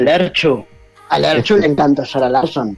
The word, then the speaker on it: Sara Larsson: Sara Larsen. – Sí, perdón que te voy a corregir, pero se dice Sara Larsson. – Larsson. – Okay. Larsson. Así, que así se pronuncia en sueco, Sara Larsson.